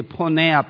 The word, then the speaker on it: porneia: And you might want to write there, I will spell it to you, porneia, P-O-R-N-E-I-A, porneia. P-O-R-N-E-I-A. It's a Greek word for sexual immorality. It's a kind of broad sense of sexual immorality. We get the word, what? Pornography from this word, porneia.